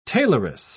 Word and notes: tailoress: British English

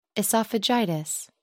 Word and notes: oesophagitis: British English

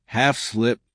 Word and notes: half slip: American English

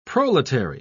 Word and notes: proletary: British English

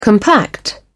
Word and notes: compact: British English

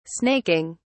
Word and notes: snaking: British English

